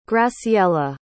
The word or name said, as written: graciela